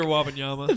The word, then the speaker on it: Wembanyama: I just don't think – Say his name real quick. We got it. – Victor Wembanyama. – Thank you. We got to correct that.